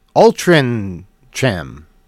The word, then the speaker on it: Altrincham: So Altrincham is pronounced Altrincham. Pronounce the C like a G.